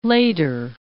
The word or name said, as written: later